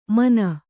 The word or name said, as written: ಮನ